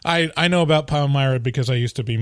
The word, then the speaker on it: Palmyra: So, um, Elias, you're in Pal- Palmyra. Is that how you say it? – Yeah, Palmyra, New York.